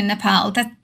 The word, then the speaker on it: Nepal: Well, it is actually Nepal. That's how they say it.